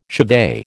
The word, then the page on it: chevet: American English